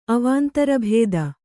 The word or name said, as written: ಅವಾಂತರಭೇದ